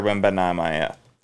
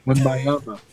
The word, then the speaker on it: Wembanyama: You know, and Connor Bedard was essentially the same level of pick as what for hockey as was the Victor Wembanyama. Excuse me, I really butchered that name. – Wembanyama.